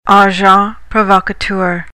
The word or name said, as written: agent provocateur